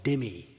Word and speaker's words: dhimmi: I need to introduce you to a word called dhimmi, D-H-I-M-M-I. – Vreau să vă introduc unui cuvânt dhimmi. – D-H-I-M-M-I.